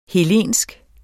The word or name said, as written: hellensk